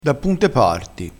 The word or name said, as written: punto